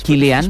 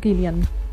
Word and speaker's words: Cillian: O sigui, ara hem de sentir, aneu preparant el vostre anglès, hem de sentir l'actor Cillian Murphy. – Cillian. Es pronuncia Cillian, sí.